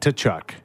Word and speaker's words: Tkachuk: And do you guys pronounce it Tkachuk? – We pronounce a T. – Like Tkachuk. – Tkachuk. Yeah. – Tkachuk. Yeah. – That's his problem. Yeah. That's soft T.